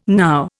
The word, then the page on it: now: Indian